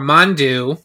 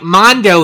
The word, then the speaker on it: Mondo: Or hey, take two, say it like Mondo this time instead of Mondu.